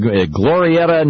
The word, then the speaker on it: Glorieta: On line one, we have Scott from Glorieta, New Mexico.